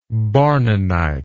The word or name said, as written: bournonite